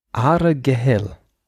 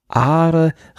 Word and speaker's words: Argyll: Ich habe übrigens gefunden hier, ich habe die Aussprache gefunden. – Jetzt kommt. – Argyll. Argyll. – Okay. – Wobei das nicht G und das L hinten im Hals wie im Rheinland gesprochen werden. Also Argyll.